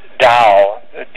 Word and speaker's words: Tao: The sense of Tao, the T-A-O, sometimes D-A-O, it's pronounced as a D in any case,